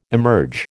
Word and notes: emerge: American English

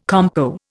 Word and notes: compo: Indian

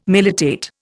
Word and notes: militate: Indian

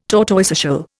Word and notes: tortoiseshell: Indian

